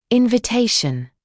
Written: invitation

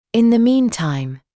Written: in the meantime

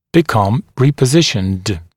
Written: become repositioned

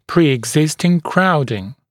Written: pre-existing crowding

